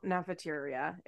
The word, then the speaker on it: Nefertiti: Nefertiti is tough. – Rhymes with cafeteria. – It rhymes with cafeteria. Like, that's what I kept thinking when I was like, Nefertiti.